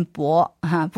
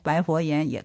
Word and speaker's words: 白: 应该是念薄哈，不过因为后面紧接着佛，这薄佛言就不太好，不太顺哈，所以大家都念成白佛言也可以，这个都无所谓，约定俗成就可以哈。